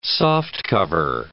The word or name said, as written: softcover